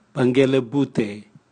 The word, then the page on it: bwangelebute: Raga